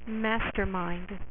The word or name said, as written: mastermind